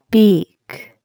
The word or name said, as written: beak